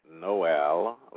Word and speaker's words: Noel: This is Noel from Oklahoma right now. – No, how do you say your name again? – Noel, like Christmas.